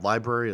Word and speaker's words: LBRY: I actually work for a company that it's called Library. It's spelled LBRY.